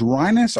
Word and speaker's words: Rinus: And uh, I guess you say his name is Rinus, R I N U S, Rinus VeeKay.